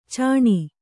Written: ಚಣ್ಣ

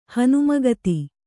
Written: ಹನುಮಗತಿ

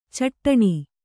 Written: ಛಟ್ಟನೆ

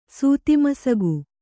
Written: ಸೂತಿಮಸಗು

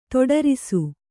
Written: ತೊಡರಿಸು

